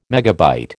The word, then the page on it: megabyte: American English